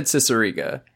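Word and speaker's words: Cicierega: I watched like TED Talk thing that he did 10 minutes ago, and he said Ciceriga. – So I'm going with Ciceriga. – According to Ciceriga, it is a prequel to Mouth Sounds.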